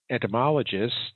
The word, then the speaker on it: entomologist: In your intro, I see that you're an entomologist, if I'm pronouncing it right, first of all. – That's can you explain? – Correct. Yes, sir.